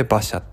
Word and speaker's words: Bacha: BACHA これで馬車って読むみたいですあのそれで、えーと、馬車コーヒーで検索してもらうとあのサイトが出てくると思いますはい。